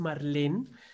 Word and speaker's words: Marlene: Hoy me acompaña, hoy me acompaña Marlene se escribe Marlene, pero es Marlene, Marlene Ramírez.